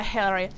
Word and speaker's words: Hillary: E poi la tua pronuncia di Hillary. – Vabbè, era più americana possibile. – Americana, Hillary.